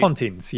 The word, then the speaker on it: Pontins: Is this Pontins? This has to be a UK thing, right? – Pontins, yes. Yeah.